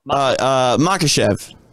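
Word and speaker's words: Makhachev: He's got this idea that he wants to have the title shot, but I always say his name also wrong. Makachev. – Makachev. – Thank you.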